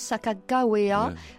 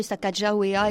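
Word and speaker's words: Sacagawea: en fait, euh, souvent, plus souvent, on prononce Sakagawea, euh, mais on peut aussi prononcer Sakajawea. Il y a une discussion entre les historiens américains.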